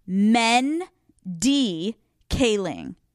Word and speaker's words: Mindy Kaling: I have a team of people that send me these things, and in parentheses on the fucking docket, it says, "Alex, please pronounce it as so. Mendy Kaling."